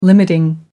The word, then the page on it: limiting: American English